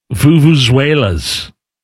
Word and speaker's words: vuvuzela: Do you know if I'm pronouncing it correctly, Allison? Vuvuzela? – I'd say so. Yeah, Vuvuzela? – Sure. – Vuvuzela? FIFA. – That's right.